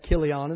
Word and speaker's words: Chilion: Mahlon and Chilion." And some people pronounce that Kilion, and that's okay, but I-C-C-H-I-L-I, that's Chili to me.